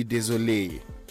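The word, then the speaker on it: désolée: If you're male, you say je suis désolé, and if you're female, you add an E. Okay, so that gives you double E at the end, full stop, same pronunciation. Je suis désolé.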